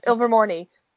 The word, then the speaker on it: Ilvermorny: All right, so Ilvermorny, I think that's how you say it. Yes, Ilvermorny. It's probably the only one I can pronounce correctly out of the lot of them.